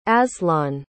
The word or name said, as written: azlan